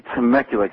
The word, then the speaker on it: Temecula: It would be Temecula, California.